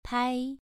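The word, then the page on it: pai: tone 2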